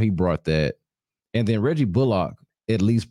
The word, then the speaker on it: Reggie Bullock: Reggie Bullock.